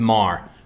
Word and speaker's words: Marr: Every time I do slightly correct your pronunciation of my name, it's Marr. But close enough. Rhymes with car, bar, star, far.